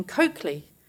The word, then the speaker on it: Colclough: This pronunciation was subsequently confirmed through two different sources. One was a newspaper article relating to her son, William Caesar Sarsfield Coakley, which referred to Coakley, C-O-K-E-L-Y, in brackets after his name, as a guide to the correct pronunciation.